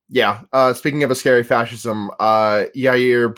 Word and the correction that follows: Jair: Bos- Bolsonaro, I believe, is that is that the correct pronunciation? – of Brazil's president? – Yes. Yeah. Jair Bolsonaro. Uh, he is just like, you know, I mean, we like the instant that he was. Oh, Jair. Sorry.